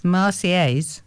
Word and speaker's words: Marseillaise: The Marseillaise. – Marseillaise. – Yeah. You don't say the L's are silent. – No, it's a yes sound.